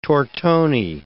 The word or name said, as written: tortoni